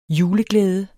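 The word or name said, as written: juleglæde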